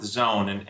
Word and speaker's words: Xone: What we're focused on with Zone, and for your listeners, it's spelled X-O-N-E.